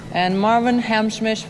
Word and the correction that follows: Hamlisch: for who? – The way we were. – Who? – Hamlisch. Hamlisch.